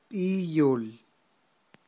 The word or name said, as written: b'iyol